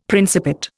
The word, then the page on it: Principate: Indian